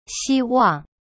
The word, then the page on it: 希望: xi-wang